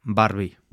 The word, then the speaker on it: Barbie: Por cierto, voy a pronunciar Barbie, que es como lo decimos en español.